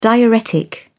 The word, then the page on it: diuretic: British English